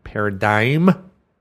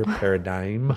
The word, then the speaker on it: paradigm: I am presenting a workshop on the new healthcare marketing paradigm. My theme for the year, paradigm. – G is silent.